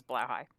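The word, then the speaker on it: Blåhaj: And, well, I follow a podcaster, and that's the pronunciation we've settled on is Blahdate. B-L-A-H-A-J.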